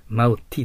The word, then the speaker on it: multi: Multi です、発音は。